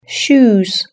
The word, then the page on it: shoes: British English